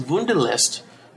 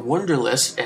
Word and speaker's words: Wunderlist: Let's get into Wunderlist, because Wunderlist, or Wunderlist, as you know, some of us Americans might pronounce it, is really cool for task management and workflow.